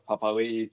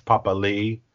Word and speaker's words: Papali'i: But so Josh Papali'i. Apologise for saying Papali'i earlier. Now he changed the pronunciation this year.